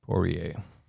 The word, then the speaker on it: Poirier: Poirier. Is that how you say it? Poirier? – Poirier.